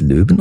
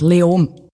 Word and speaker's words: Leoben: Spricht man es Leoben aus? Wie spricht man das überhaupt aus? – Eigentlich fast kein Konsonant. Leom.